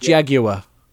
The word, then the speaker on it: Jaguar: Okay, I think it's pronounced Jaguar.